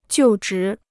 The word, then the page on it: 就职: jiù zhí